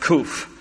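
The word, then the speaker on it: Kuf (קוף): Starting at verse 145, this section here is entitled by the Hebrew letter Kuf. It's pronounced Kuf.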